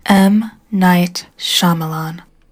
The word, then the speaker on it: Shyamalan: M. Night Shyamalan.